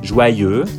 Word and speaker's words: joyeux: Yeux, os olhos, joyeux, alegre, heureux, feliz. Nessas três palavras, a dificuldade da pronúncia é devida ao som E, que em francês é igual à pronúncia da letra E do alfabeto. E.